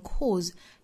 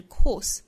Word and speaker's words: course: 一个呢是连读同化需要我们掌握，还有一个就是加出这个小小的 r 的发音 chart your own course，最后的 course 尾音呢是一个轻辅音的 s 的发音，所以注意不要读成 cause，应该是 course course。